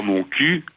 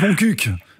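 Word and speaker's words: Montcuq: Et puis il y a le Lot-et-Garonne en dessous, il y a mon cul, le fameux village. – Ça se prononce mon cuc, en fait.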